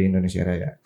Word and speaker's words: Indonesia Raya: karena karena gini, uh, dulu kan pada zaman dulu itu Y itu jadi J. Oh iya, eja apa apa sih ejaannya? Uh, eh, uh, eh, C itu uh, di tulisannya jadi TJ. – Hmm, iya nah, iya. Kan? iya. – Jadi uh, Indonesia Raja kini diganti jadi Indonesia Raya.